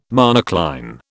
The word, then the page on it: monocline: British English